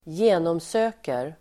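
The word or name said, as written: genomsöker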